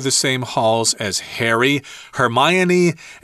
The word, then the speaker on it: Hermione: Yeah, Hermione.